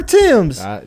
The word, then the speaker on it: Thames: The River Thames.